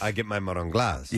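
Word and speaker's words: marron glacé: I said it wrong, didn't I? – Can I have some of that macaroni glass? – All right.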